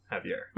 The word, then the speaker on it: Xavier: I used to go to places like Granada or Cozumel, and they would pronounce my name. Xavier.